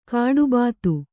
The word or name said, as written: ಗಡಿಭತ್ತ